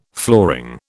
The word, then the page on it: flooring: British English